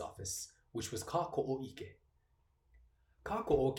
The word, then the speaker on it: Kāko’o: In terms of pronunciation, the A in Kāko’o has a Kāko’o over it, which means the emphasis is placed on that first vowel sound.